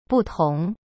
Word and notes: 不同: butong

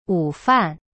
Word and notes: 午饭: wu fan

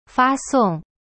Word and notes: 发送: fa song

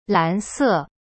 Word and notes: 蓝色: lan se

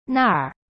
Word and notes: 那儿: na-r

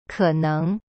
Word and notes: 可能: ke neng

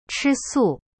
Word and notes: 吃素: chi su

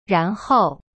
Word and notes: 然后: ran hou

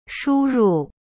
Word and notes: 输入: shu ru

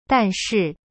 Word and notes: 但是: dan shi

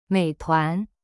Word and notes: 美团: mei tuan

